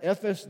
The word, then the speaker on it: Ephes: They were gathered together at Succoth, which belongeth to Judah, and pitched between Succoth and Azekah in Ephes, that's how you pronounce that, Ephes Damim.